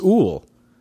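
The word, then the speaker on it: Úll: Our second sponsor today, this week, both are true, today and this week, is Ool, a conference. That's spelled U-L-L, but the U has a little accent thing over it.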